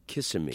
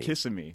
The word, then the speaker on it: Kissimmee: And they used to pronounce it Kissimmee. – Kissimmee. – When I was growing up.